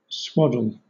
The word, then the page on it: swaddle: British English